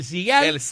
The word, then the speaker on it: Seagal: y Cigal se escribe S-E-A-G-A-L. – Ahí estamos, ahora sí. – Seagal, así. El Cigal.